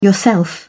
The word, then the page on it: yourself: British English